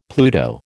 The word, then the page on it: Pluto: American English